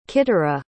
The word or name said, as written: qitarah